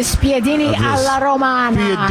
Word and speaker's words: spiedini alla romana: of the spiedini alla romana. – Spiedini alla romana. – Bravo. Hai detto bene.